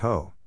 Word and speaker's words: Hoh: The Ho Rainforest, pronounced Ho, takes its name from the Ho River, which flows south from Mount Olympus to the Pacific coast.